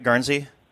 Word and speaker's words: Guernsey: Steve asks, we are visiting Guernsey for, did I say that right, Guernsey? – Mm-hmm.